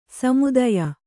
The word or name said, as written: ಸಮುದಾಯ